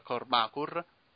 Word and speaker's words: Kormákur: Allora, parliamo di Contraband, film diretto da Baltasar Kormákur, che c'è l'accento sulla A.